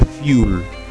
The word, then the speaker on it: fuel: Combustible, ¿no?, que se dice fuel en inglés y se pronuncia fuel, ¿eh? Pues de una UE sacamos una IU, ¿no?